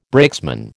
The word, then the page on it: brakesman: American English